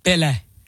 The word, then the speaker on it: Pelé: Is it pronounced Pelé or Pelé? – Pelé. – Okay. – Because it's, you know, enunciation, Portuguese, Brazilian. Pelé.